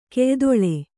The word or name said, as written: ಕೆಯ್ದೊಳೆ